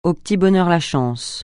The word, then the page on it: au petit bonheur (la chance): francais